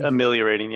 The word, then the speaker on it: ameliorating: Did I say that right? Ameliorating? – Ameliorating, yeah. – You nailed it.